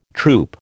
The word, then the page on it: troupe: American English